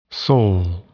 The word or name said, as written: sol